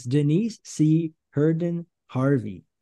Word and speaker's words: Denise C. Herndon Harvey: Today, we have another amazing guest joining us, Denise C. Herndon Harvey.